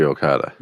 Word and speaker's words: Okada: Surely Okada.